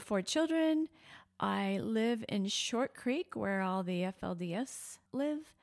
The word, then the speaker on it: Short Creek: You live in Short Creek, how the locals say it, Short Creek. That's right, Short Creek.